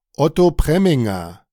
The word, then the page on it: Otto Preminger: [ˈɔtoː ˈpreːmɪŋɐ]; German